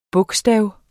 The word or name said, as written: bogstav